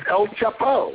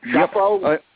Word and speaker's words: Chapo: It's El Chapo. Isn't that how you pronounce it? Chapo? – Yep.